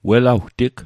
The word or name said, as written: welawtig